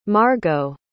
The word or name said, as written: margo